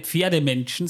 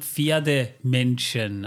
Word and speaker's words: Pferdemenschen: Aber sie sind keine Pferdemenschen, sondern Pferdemenschen. Also genau. Die Betonung ist ein Unterschied.